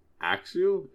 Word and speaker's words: Axew: ransom, but Meowth, Ash, Pikachu, Snivy, and Scraggy helped get back Axew? Is it Axew? – Yep, that is how you pronounce it.